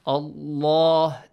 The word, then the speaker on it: Allah: The rule says that if you have a fatha or kasra or dhamma sound before the word Allah, the word Allah must be pronounced with a heavy sound. Allah, this way.